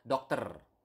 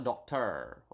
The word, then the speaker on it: dokter: You need to pronounce the R. Okay, so dokter, not doctor or something like that, but dokter.